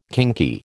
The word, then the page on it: kinky: American English